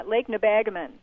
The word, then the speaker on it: Lake Nebagamon: With us from Nebagamon. Am I pronouncing that anywhere close to being right? – No, nowhere near. Lake Nebagamon.